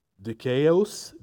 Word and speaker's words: dikaios: Now, the word that is used here is a Greek word, and again, I'm going to try to pronounce this correctly. It's dikaios, D-I-K-A-I-O-S, dikaios.